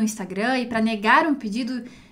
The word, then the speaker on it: Instagram: Então, no português a gente vai falar bem anasalado, Instagram, e em italiano Instagram.